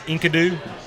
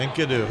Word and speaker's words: Enkidu: I believe it's pronounced Enkidu. – Yeah, Enkidu. Enkidu.